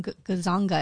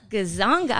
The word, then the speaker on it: Gonzaga: So, Matt Shea grew up in East Washington State and graduated from Gonzaga University in 1996 with a bachelor's degree, and a Juris Doctor degree from Gonzaga. Am I saying that right? – Gonzaga.